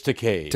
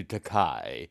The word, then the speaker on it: Takei: Actually, is it Takei or Takai? – It's Takei. – Takei. – Rhymes with okay. – All right. – But I don't object to Takai, which is a legitimate Japanese word spelled T-A-K-A-I, which means expensive.